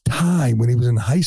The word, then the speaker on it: Thai: According to a friend, DeSantis would tell dates he liked Thai food, but pronounced it thigh.